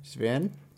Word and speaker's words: Zwehrn: This story is from Zwern. I still don't know how to pronounce that. – Zwern? – Z-W-E-H-R-N. – Z-W-E-H-R-N. – Zwern? – Zwern.